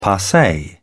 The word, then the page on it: passe: British English